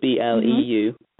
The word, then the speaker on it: Bleu: Bleu is spelled the French way, so it's Bleu.